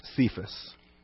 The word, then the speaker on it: Cephas: Cephas, or typically in English pronounced Cephas.